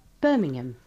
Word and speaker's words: Birmingham: El jamón es ham, H-A-M, y se pronuncia ham. Pero aquí desaparece. Vamos a escuchar alguna. – Birmingham.